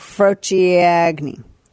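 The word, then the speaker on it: frociaggine: It's Frociagni. – Is that how it's pronounced? – Frociagni is apparently what it is.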